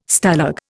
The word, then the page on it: Stalag: Indian